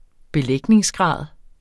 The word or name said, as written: belægningsgrad